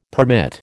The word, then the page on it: permit: American English